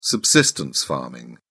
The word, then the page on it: subsistence farming: British English